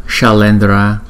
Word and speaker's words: Shalendra: Chữ S là cong lưỡi lại, bởi vì nó có dấu sắc ở trên đầu là sh, sh, sh, shalendra, shalendra. Rajaya, hai chữ A có dấu gạch ngang trên đầu, chúng ta sẽ đọc hai nhịp.